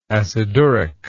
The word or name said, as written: aciduric